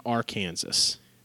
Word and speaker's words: Arkansas: Moving on, Liberty and Arkansas. – It's pronounced Arkansas.